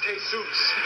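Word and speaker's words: Jesús: Hey, Bob, that's pronounced Jesus. – Well, they spell it the same way Jesus spelled his name. – I know, but you don't say it that way.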